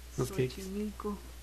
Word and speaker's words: Xochimilco: Y Xochimilco, que suena como S, no es que Xochimilco.